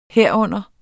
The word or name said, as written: herunder